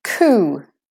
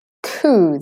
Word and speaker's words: coup: Another word, coup. The P is silent there. Coup. The OU is saying OO in this word.